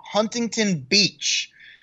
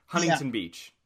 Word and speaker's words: Huntington Beach: I love when people actually pronounce the T. It, like, here in California, it's Huntington Beach. Anyone outside of California, Huntington Beach. Even though it's spelled with a T, no one says the T here in California.